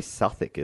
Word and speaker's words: Southwick: So after some persistence from Southwick, it's probably Southwick, is it? – No, Southwick sounds good.